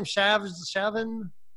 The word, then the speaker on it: Shavzin: How do you say your last name? Shavzin? – Shavzin, just like it sounds. Yeah, perfect. Yeah, I met David Shavzin on a we did a webinar maybe 2 weeks ago now with a mutual friend of ours, Cherish and Bridget.